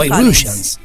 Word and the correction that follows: White Russian: White Russians.